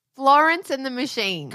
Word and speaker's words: Florence and the Machine: I don't know if you've heard of a little band called Florence and the Machon. – What are you saying? – Actually, can't say it now. I can't say her name. Florence and the Machine.